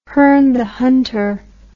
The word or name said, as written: Herne the Hunter